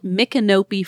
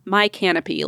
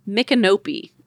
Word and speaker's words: Micanopy: It's called Micanopy, Florida. And she said, not my canopy like I thought it was at first. Oh. Micanopy. Okay. Does not look like it should be pronounced that way, but here we are.